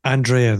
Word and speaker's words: Andrea: Andrea, then.